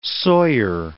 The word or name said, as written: sawyer